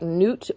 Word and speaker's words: Nut: Just like Newt or Nuit. Newt and Geb. Not Nut and Geb. Newt and Geb. I'm just telling you how they said they want to hear their name pronounced. They don't want to be called Mut and Nut. They want to be called Moot and Newt.